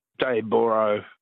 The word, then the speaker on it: Dayboro: Daybra. It's spelled O-R-O through me. – It is. – Yeah. It should be Daybra, B-R-A-H. – Yeah. Everyone south of the border calls it Dayboro, but yeah, fair enough.